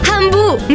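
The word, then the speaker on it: Hambu: Ambu si scrive con l'H. – Ambu, mi piaci tu.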